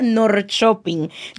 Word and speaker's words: Norrköping: Y ahí me llevaron a esa ciudad que se pronuncia Norrköping.